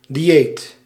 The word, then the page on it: cibo: /ˈʧi.bɔ/